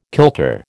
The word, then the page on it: kilter: American English